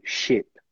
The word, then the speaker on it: ship: A segunda é um short vowel sound. Ele é mais fechado. Ship. Ship. É como se a gente estivesse falando um E com acento circunflexo. Ship.